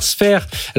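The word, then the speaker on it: Sfeir: Sphere, alors pour ceux qui nous écoutent en radio, ça s'écrit S-F-E-I-R. C'est bien ça.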